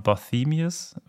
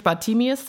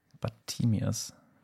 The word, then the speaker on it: Bartemius: heißt das Bartemius oder sagt man Barthemius? Oder wie wird das ausgesprochen? – Also auf Englisch würde ich Bartemius sagen. – Bartemius.